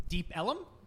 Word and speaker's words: Deep Ellum: Yeah, so far where Dallas Comedy Club is in Deep Ellum.